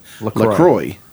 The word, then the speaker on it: La Croix: That is how the French pronounce it, but we pronounce it La Croix. – And this whole time, feel like with LaCroix, we're meeting somewhere in the middle because we're not pronouncing the X. Mm-hmm. We're not saying LaCroix.